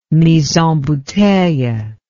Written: mis en bouteilles

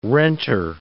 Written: renter

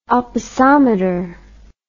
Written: opisometer

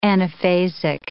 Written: anaphasic

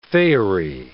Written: faerie

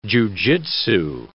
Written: jujutsu

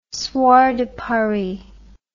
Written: Soir de Paris

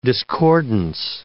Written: discordance

